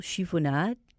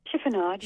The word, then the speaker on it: chiffonade: red pepper soup with basil chiffonade. Did I pronounce that correctly? – Chiffonade, yeah.